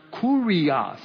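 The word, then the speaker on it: kurios (κύριος): And that is the word in, and the word for the Lord, pronounced kurios, in the Lord.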